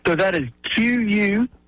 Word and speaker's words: Quixote: So that is Quixote Club, like Don Quixote.